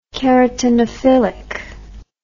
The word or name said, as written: keratinophilic